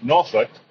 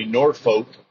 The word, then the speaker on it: Norfolk: Now, you notice when I said Norfolk, it's actually Norfolk, Virginia. However, if you're from Virginia, it's pronounced Norfolk. A lot of times on movies and TV shows, they'll say Norfolk, which is absolutely incorrect.